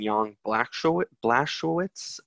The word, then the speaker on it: Blachowicz: Jan Blachowicz. I think I'm saying that right. – Yeah, I think you're saying that right.